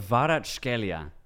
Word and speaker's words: Kvaratskhelia: How do you say his name, bro? – Kvaratskhelia. Or the hell did you say to me? – or more simply, Kvaradona.